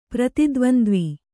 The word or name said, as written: ಪ್ರತಿದ್ವಂದ್ವಿ